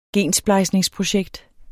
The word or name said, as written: gensplejsningsprojekt